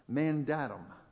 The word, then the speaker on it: mandatum: The term Maundy that appears in the definition of today comes from an old English word that is derived from the Latin word mandatum. Is there any Latin scholars here? Did I pronounce that correctly? Brett? That's good.